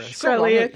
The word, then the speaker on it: Shkreli: Basically, you saw that the farmer bro, Martin Shkreli, is that how you say it? I can't even remember. – Shkreli, so, Shkreli, yeah.